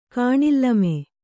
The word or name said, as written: ಕಾಣಿಲ್ಲಮೆ